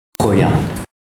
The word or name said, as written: roya mon frere